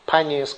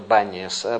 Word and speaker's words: Баниас: Все комментаторы упоминают здесь Паниас, который, поскольку арабы не произносят букву П, они назвали это Баниас.